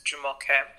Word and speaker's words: Jomoke: But much like Tumu, I often allowed people to say my name incorrectly, but the correct pronunciation is Jomoke.